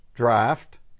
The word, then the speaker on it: draught: You say, well, how could D-R-A-U-G-H-T be draught? Well, how do you pronounce L-A-U-G-H? Oh, laugh. Draft, laughed.